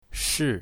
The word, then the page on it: shi4: mandarin